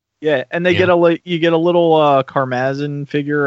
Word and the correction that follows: Crimzy: Am I saying that right? – Crimzy. – Yeah, Crimzy. Yeah, there you go.